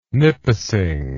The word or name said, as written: nipissing